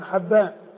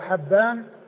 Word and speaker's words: حبان: فحبان هو الفتح الهائي وليس بكسرها، وهو ابن هلال وهو ثقة حديثه عند أصحاب الكتب الستة.